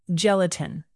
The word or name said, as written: gelatin